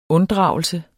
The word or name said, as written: unddragelse